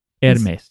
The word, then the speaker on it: Hermes: I think it's Hermes. – Hermes. You might be right. – Yeah, that's how it is. Anyway. – Accent the first syllable.